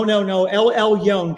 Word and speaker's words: Elyon: Did I say it wrong? – No. Elyon, God Most High.